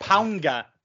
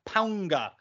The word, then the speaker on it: Paunga: You also got Paunga wrong too. – It's Paunga. – It's Paunga. Paunga. – There's like the N sound in it. Interesting.